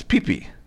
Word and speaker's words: Phi Phi: But we're going to a place called PP Island. It's spelled P-H-I-P-H-I, but it's pee-pee.